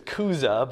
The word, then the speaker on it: Chuza: It's Kuza, but it's okay. I would pronounce, there's no Ch sound, right? It's funny, but we'll call it Chuza because that's what it looks like.